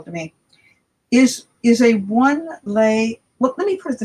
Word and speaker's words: lei: L-E-I. – L-E-I. Mm-hmm. And how do you say it again? – Lei. – Lei. Oh, like a Hawaiian lei.